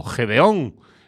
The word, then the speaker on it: Gideon: Pues creo que lo único más que hay en caña es lo que vamos a escuchar ahora, que es una banda llamada Gideon o Gedeón, como se pronuncia en la traducción castellana.